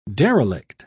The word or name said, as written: derelict